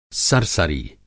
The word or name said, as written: सरसरी